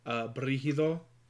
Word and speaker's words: Brigido: Brigido.